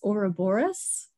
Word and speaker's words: Ouroboros: And it's funny because you pronounce it differently than I do, but I think maybe now that I think about it, I've only ever read it. So I've pronounced it in my own mind as Ouroboros. – I think that that's another pronunciation. Okay.